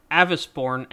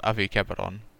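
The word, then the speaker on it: Avicebron: The English dub of Fate Apocrypha as Caster of Black, Avisborn, and Victor Frankenstein. – Oh, it's pronounced Avi Keberon.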